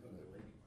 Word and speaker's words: Kundalini: It's the Kulananji or something like that. It's the experience in yoga or in Hindu. In Kundalini. – Huh? – Kundalini.